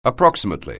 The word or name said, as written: approximately